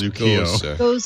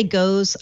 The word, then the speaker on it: Gose: It's pronounced G-O-S-E, but it's pronounced Gozer. – Gozer. Yeah, some people say goes. I've seen people say goes. Some say goes. I say gozer because I'm a Bostonian and I throw an R on anything.